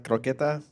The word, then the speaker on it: Croqueta: What is it? Croquette? – Oh, like croqueta? – Croqueta, yeah.